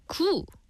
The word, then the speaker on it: 구: The number 9 is pronounced 구, which sounds like 귀, a Korean word for ear.